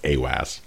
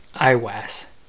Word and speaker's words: Aiwass: His holy guardian angel's name was AWAS. AIWASS. You guys pronounce it how you want. I'm going to go with AWAS. – IWAS.